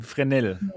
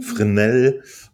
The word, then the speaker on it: Fresnel: Ich, ich, ich, ich glaube, es gibt bisher nur asphärische und äh, äh, Fresnel-Linsen, äh, wie wird das ausgesprochen? Fresnel. – Fresnel. Und äh, die haben sich was komplett eigenes ausgedacht und äh, ist echt schade.